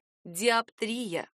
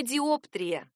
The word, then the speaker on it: диоптрия: Единого правила тут нет, поэтому в любом случае всегда лучше обращаться к словарям, которые подскажут, правильно, индустрия и диоптрия, не диоптрия.